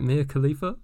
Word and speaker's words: Mia Khalifa: Mia Khalifa? – Yeah.